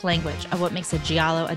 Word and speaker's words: giallo: Is it pronounced giallo or giallo? – Giallo. – Just like yellow.